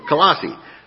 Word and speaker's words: Colossae: I had to ask Darla, help me out. Tell me how to pronounce this word. Colossae.